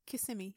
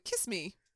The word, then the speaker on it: Kissimmee: Is that how you say it? – Kissimmee, yes. – I was like, kiss me. Kissy me. – It looks like it should be, but it's kissy me.